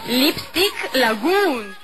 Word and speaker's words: Lipstick Lagoon: Lipstick Lagoon.